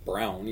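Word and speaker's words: Braun: Who pronounces it Brown, even though it's B-R-A-U-N.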